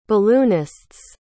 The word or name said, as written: balloonists